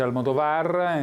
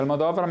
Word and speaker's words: Almodóvar: Sì, in effetti c'è chi lo pronuncia Almodóvar, in realtà si pronuncia Almodóvar, ma addirittura lui rinuncia anche al suo nome di battesimo, perché è diventato quasi un marchio.